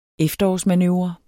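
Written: efterårsmanøvre

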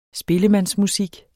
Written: spillemandsmusik

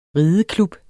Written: rideklub

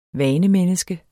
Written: vanemenneske

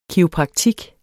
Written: kiropraktik

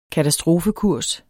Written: katastrofekurs

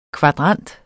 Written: kvadrant

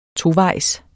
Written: tovejs

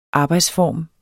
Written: arbejdsform

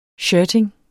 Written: shirting

